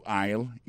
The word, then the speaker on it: aisle: Aisle là c'est A I S L E. You prononce aisle. Li marqué aisle. Quand on monde dit comme ça oui, marche avec tout le bol bleu ma base aisle. Moi c'est pas aisle ça non? Li marqué aisle, mais c'est aisle le prononce.